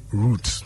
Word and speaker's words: route: So we say root. – It's root. – Yes. – Just the same as you say the root of the tree. – The same way. – So it's root, root. – Root, root. – Okay. – However, the Americans say either root or out. – Okay. – Now, route is R-O-U-T.